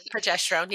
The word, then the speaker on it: progesterone: this dramatic drop in progesterone. Progesterone? Am I saying that correctly? Thank you. – Progesterone. Yeah.